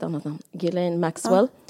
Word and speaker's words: Ghislaine: Vad jo, Jo, men det uttalas Ghislaine. – Ja. Okay. Det känns som jag är den enda i Sverige som liksom... Jag har aldrig pratat med någon om det, men i England säger man Ghislaine. Ghislaine mm. uttalas det. Om du har lyssnat på en podd från the BBC så säger de Ghislaine Maxwell.